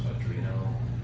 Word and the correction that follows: Arduino: Arduino, whatever that's called.